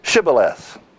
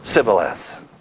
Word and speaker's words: Shibboleth: If he said no, then they would say to him, oh, then say Shibboleth. And they would say Sibboleth, for he could not pronounce it right.